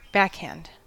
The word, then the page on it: backhand: American English